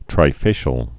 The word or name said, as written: trifacial